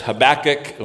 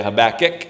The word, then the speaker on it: Habakkuk: If you want to go there, people pronounce it different ways. There's Habakkuk, or some people say Habakkuk. Some people say Habakkuk. You know, there's all kinds of different ways. It doesn't really matter how you say it.